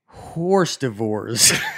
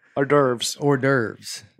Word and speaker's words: hors d'oeuvres: The worst word I butchered while reading out loud was horse divorce. – Hors d'oeuvres. – Yeah. I pronounced it as ours devours.